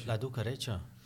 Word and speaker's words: La Ducareccia: Zo spreek je het uit. – Ja, La Ducareccia.